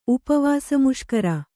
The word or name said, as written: ಉಪವಾಸಮುಷ್ಕರ